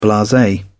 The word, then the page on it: blase: British English